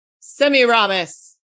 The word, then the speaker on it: Semiramis: Semiramis.